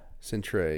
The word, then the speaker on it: Centre: Centre, yeah, but Centrex. It's pronounced Centre.